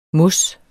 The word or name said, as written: mos